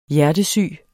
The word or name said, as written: hjertesyg